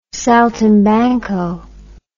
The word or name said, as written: saltimbanco